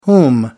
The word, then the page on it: whom: British English